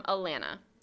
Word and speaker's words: Alana: This is from Alana. It rhymes with banana.